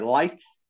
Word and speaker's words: lit: temos Lit, ou melhor, Light, e não confundir com light de luz, mas se escreve L-I-T, mesma pronúncia.